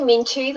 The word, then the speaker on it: Myintu: It's actually Mintu, the Y is silent.